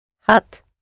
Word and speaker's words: had: H-A-D. Had. I had, you had, he had. Okay. H-A-D might be pronounced in Dutch as hat. – Hot. Yes, good.